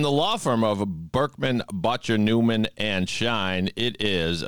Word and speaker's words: Bottger: It's Berkman, Botcher, Newman, Schein. – Yeah, it's not. Botcher.